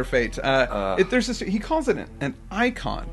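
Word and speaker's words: eikon: It's an antiquated spelling of icon. – Oh, okay. – So it's pronounced the same way even. It's just spelled funny.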